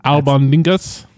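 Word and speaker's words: Albóndigas: Albondigas? – Yeah, man.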